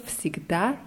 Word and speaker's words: всегда: Ja, nur bei всегда ist die Betonung auf a. Моя подруга всегда ездит в университет на велосипеде.